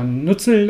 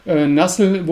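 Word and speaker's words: Nuzzel: Äh, Anlass war, äh, der wirkliche Anlass war die, die, die, Einstellung von Nassel oder Nutzel oder ich glaube, Nassel ist äh, die korrekte Aussprache im Englischen, ja.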